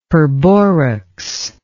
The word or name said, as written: perborax